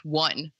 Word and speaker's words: one: And I like didn't know until like I was probably halfway through high school that it was pronounced one, like the number one, instead of like W-O-N. – Yeah. There's certain words for me. – Foot is pronounced foot and it's F-O-O-T.